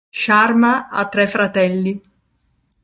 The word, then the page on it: fratelli: Italian